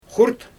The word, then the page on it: xurt: Xyzyl